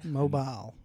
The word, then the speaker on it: Mobile: He said Mobile, Alabama. – Mobile. – Yeah, that's how they say it. You say mobile to somebody from Mobile, Alabama, and they be like, I don't know where the hell mobile is.